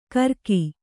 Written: ಕರಕಿ

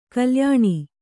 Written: ಕಲ್ಯಾಣಿ

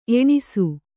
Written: ಎಣ್ಣಿಸು

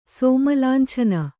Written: ಸೋಮಲಾಂಛನ

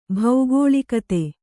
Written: ಭೌಗೋಳಿಕತೆ

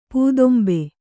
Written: ಪದಿಂಬು

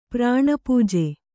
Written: ಪ್ರಾಣಪೂಜೆ

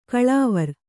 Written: ಗಲವಾರ

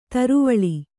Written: ತರುವಳಿ

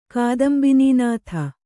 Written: ಕಾದಂಬಿನೀನಾಥ

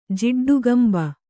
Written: ಜಿಡ್ಡುಗಂಬ

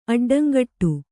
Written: ಅಡ್ಡಂಗಟ್ಟು

